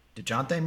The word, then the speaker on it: Dejounte: I mean, other names that are out there, maybe Deontay Murray, Dejounte Murray. I can't say his name, Deontay.